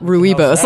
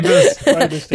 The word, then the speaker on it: rooibos: Yeah, not rooibos, like I've been pronouncing it. – Rooibos. – Because that comes from an Afrikaans word.